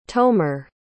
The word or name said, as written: tomer